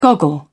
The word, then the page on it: goggle: British English